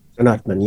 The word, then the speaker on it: Anatman: You say, a Buddhist would say that my problem is that I don't understand Anatman. Did I say that correctly? – Anatman, yeah.